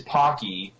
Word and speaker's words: Pocky: And when I say take all, I mean winner is right about the pronunciation of Pocky, which is Pocky and not Pokey, that's retarded.